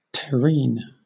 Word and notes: terrene: British English

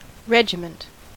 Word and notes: regiment: American English

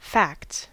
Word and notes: facts: American English

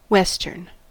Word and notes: western: American English

